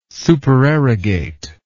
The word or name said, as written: supererogate